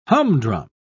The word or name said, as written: humdrum